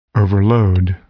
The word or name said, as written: overload